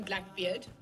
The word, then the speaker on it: Blackbeard: And you got the name correctly. It's Gillian Blackbeard, as in Blackbeard like the pirate.